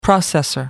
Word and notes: processor: American English

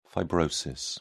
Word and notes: fibrosis: British English

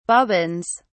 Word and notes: bobbins: British English

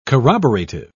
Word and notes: corroborative: British English

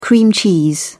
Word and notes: cream cheese: British English

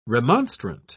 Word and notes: remonstrant: British English

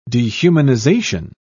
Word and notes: dehumanisation: British English

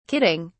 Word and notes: kidding: British English

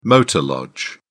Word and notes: motor lodge: British English

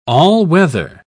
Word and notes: all-weather: British English

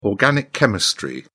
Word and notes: organic chemistry: British English

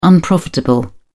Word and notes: unprofitable: British English

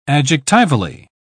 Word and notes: adjectivally: British English

